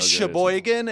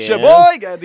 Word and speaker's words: Sheboygan: Yeah, they said Sheboygan. It's actually Sheboygan or whatever. – Sheboygan. – Sheboygan. The emphasis is on the Boygan.